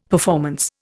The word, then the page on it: performance: Indian